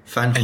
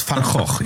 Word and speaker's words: Van Gogh: Americans say Van Gogh. – Van Gogh, yes, because they can't do the kh sound.